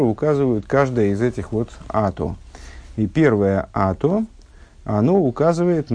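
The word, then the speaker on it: ато (אתה): Здесь в посуке встречается три раза слово «ато», «ты», Э, и первый и последний раз оно пишется как «ато», а средний раз, когда в отношении того, что ты сделал небеса и землю, э, оно пишется почему-то как, почему-то без «г» конечного. Э, несмотря на это, читается как «ато».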